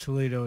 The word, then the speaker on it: Toledo: Did you ever play, when you were out at Kent State, did you ever play out there in Toledo, Ohio? Did you ever get to play Inverness? – No, it's not called, it's not pronounced Toledo. It's Toledo.